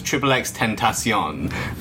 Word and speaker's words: XXXTentacion: It's XXXTentacion.